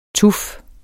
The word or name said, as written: tuf